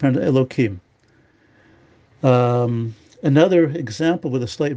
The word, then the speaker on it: Elohim: In that case, you pronounce it as Elohim, or properly if you say the whole sentence.